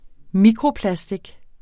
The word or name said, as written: mikroplastic